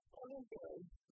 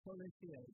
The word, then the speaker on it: Coliseu: It's Colisue. – Colisue, which is C-O-L-I-S-E-U. And that's Portuguese for Coliseum, is that right? – Yes.